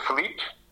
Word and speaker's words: Filipe: Yeah, so it's Filipe, and the surname is Davilés.